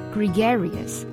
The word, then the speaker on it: gregarious: 我们分析一下这三个音节 GRE GRE 是吧，那注意呢这个 E 的发音 GRE, 然后呢 GA GA 这个比较奇怪，就是这个 A 发的是什么音呢，是 RED 那个 A 的音 GREGARIOUS。